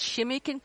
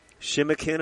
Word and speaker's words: Shimikin: We've been talking about Tea Creek, and tell us again the correct native pronunciation for the T in Tea Creek. – Okay, it's Shimikin Creek Lab. Research and Diagnostics. – Okay. Shimikin, am I close? – That's correct.